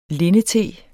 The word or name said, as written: lindete